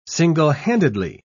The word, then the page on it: single-handedly: British English